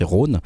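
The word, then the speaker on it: Rone: bah pourquoi pas choisir ce nouvel album de Rhône, euh, Rhône, Rhône, j'arrive jamais à le prononcer bien, c'est Rhône.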